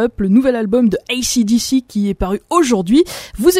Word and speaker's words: AC/DC: Je vais le dire une fois à l'anglaise pour faire plaisir à ceux à qui j'écorche les oreilles chaque semaine. Voici AC/DC avec le titre Shot in the Dark.